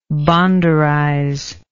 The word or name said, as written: bonderize